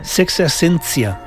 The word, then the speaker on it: Sexta Essència: Sexta Essència. És així com m'ha dit com m'han dit que es pronuncia.